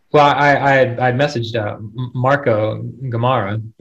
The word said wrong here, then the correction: Gamarra: and hopefully I'm pronouncing that right. And he said something. Say it again. – Gamarra. – There we go.